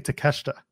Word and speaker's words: Takeshita: When Kyle Fletcher and Konosuke Takeshita are a tag team, why are they called Proto Shida? Because the I is silent in Takeshita, and it is an amalgamation of Proto Star and Takeshita.